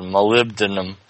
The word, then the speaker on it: molybdenum: No, I just remember people saying, somebody said molybdenum, and they were like, no, it's called molybdenum. – How do you spell it? M-O-L-I-B.